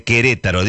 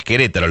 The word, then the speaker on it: Querétaro: Saludos a toda la gente de Querétaro, digo, lo pronuncio bien, no es Querétaro, lo digo bien.